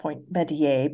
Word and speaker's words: Point Metier: It's supposed to be pronounced Point Metier, but of course, people butcher names, so I haven't called it Point Metier.